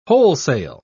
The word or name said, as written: wholesale